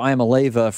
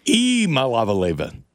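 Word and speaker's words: Iamaleava: Iamaleva. – Yeah, I pronounced it incorrectly this whole time. Yikes. – You start with E. Like you go there, you go to Tennessee's official deal. It starts with E.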